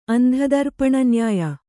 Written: ಅಂಧದರ್ಪಣನ್ಯಾಯ

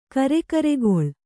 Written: ಕಿರಿಗರಕೆಲೆ

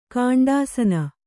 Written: ಗುಂಡಾಸಿನಿ

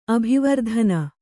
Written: ಅಭಿವರ್ಧನೆ

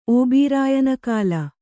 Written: ಓಬಿರಾಯನಕಾಲ

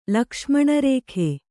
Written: ಲಕ್ಷ್ಮಣರೇಖೆ

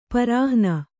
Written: ಪರಾಹ್ಣ

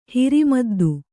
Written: ಹಿರಿಮದ್ದು

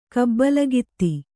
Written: ಕಬ್ಬಲಗಿತ್ತಿ

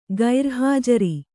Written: ಗೈರುಹಾಜರು

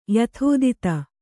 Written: ಯಥಾತಥ

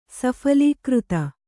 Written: ಸಫಲೀಕೃತ

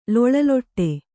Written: ಲೊಳಲೊಟ್ಟೆ

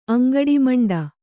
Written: ಅಂಗಡಿಮಂಡ

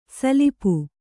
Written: ಸಲಪು

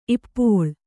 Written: ಇಪ್ಪೋಳ್

